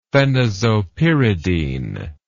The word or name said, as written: phenazopyridine